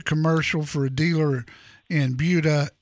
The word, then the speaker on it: Buda: Did you notice that I said that right? Buda? – You did. Most people say Buda.